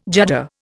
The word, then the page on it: judder: Indian